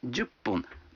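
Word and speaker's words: джупун (十分): А 10 минут – джупун. Вы заметили, что когда фун добавляется к 10, дю, то произносится пун.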